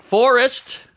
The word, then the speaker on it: Forrest: Four? – Forrest. – Forrest. – Like Gump.